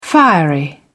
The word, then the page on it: fiery: British English